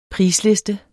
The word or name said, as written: prisliste